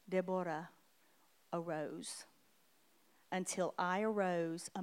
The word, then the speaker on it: Deborah: Actually, the way you're supposed to say it is Deborah. That's how you say her name. It's Deborah.